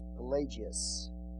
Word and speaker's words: Pelagius: The next one was given by a British monk, and his name is spelled P-A-L-A-G-E-A-S, Pelagius.